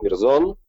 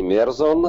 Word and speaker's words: Мерзон: Только моя фамилия произносится с ударением на последний слог Мерзон, а не Мерзон.